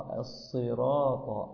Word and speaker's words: الصراط: يعني لقد أردت الإتيان بنطق حرف الصاد كما ينبغي، فجعلته كأنه حرف سين. اهدنا الصراط الصراط الصراط، اهدنا الصراط المستقيم المستقيم. هكذا أخي، الصراط الصراط.